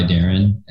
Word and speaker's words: Darin: It's at Made by Darren, and my name is spelled phonetically D-A-R-I-N. And also check out my girlfriend, Hillmatic, H-I-L underscore Matic, a play on Illmatic by Nas.